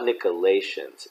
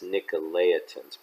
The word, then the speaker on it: Nicolaitans: And it's not Nicolaitans, it's Nicolaitans, by the way, in case you've heard it mispronounced, which I also hate.